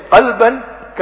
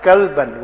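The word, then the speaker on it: qalban (قلبًا): But if he pronounces it like a Gujarati or a Punjabi, instead of a qalban, kalban, with a small calf.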